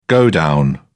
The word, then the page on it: godown: British English